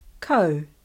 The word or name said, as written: Kau